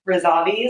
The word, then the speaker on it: Razavi: Razavi is spelled R-A. Z as in zebra, A, V as in Victor, I.